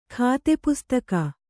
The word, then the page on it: ಖಾತೆಪುಸ್ತಕ: khāte pustaka